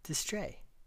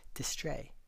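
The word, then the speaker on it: distrait: Today's five words are abeyance, A-B-E-Y-A-N-C-E, abeyance, temporary suppression or suspension. Distray, D-I-S-T-R-A-I-T, a little silent T there at the end, distray. Adjective, inattentive, preoccupied. Foment, F-O-M-E-N-T, foment, to incite or arouse. Allay, A-L-L-A-Y, allay, to lessen, ease, or smooth.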